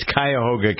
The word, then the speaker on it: Cuyahoga: how to pronounce the name of the county. It's Cuyahoga County.